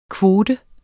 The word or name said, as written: kvote